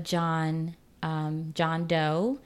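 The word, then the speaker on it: Jean Deaux: I've also been listening to a lot of John Doe. Her name is spelled J-E-A-N and her last name Doe, D-E-A-U-X.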